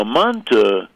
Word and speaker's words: Manta: Now, Manta, which is Manti, except that you pronounce the I as a U, and Manti, Manta, are Turkish.